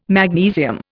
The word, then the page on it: magnesium: Indian